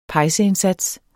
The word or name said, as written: pejseindsats